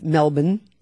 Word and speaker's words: Melbourne: We as Americans say Melbourne. – Yep. – It's Melbourne. – Melbourne. – Then we went to Cairns, which looks like C-A-I-R-N-S, and we would say Carnes.